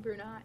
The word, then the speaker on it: Brunei: Most notably, the royal families of Malaysia and Brunei.